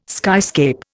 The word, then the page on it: skyscape: Indian